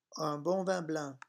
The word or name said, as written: un bon